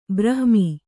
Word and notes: ಬ್ರಾಹ್ಮಿ: brāhmi

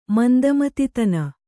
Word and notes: ಮಂದಮತಿತನ: Kannada

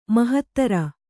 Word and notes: ಮಾಹತ್ತರ: māhattara

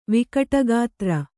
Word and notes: ವಿಕಟಗಾತ್ರ: Kannada

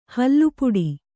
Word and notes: ಹೊಲೆಪಾಟು: kannada

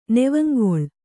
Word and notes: ನೆವಂಗೊಳ್: Kannada

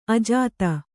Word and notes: ಅಜಾತ: kannada

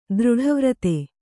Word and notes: ದೃಢವ್ರತೆ: dṛḍha vrate

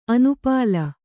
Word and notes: ಅನುಪಾಲ: kannada

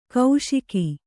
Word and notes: ಕೌಶಿಕಿ: kauśiki

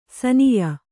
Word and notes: ಶಾನಾಯ: śanāya

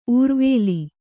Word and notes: ಉರುವೇಲು: uruvēlu

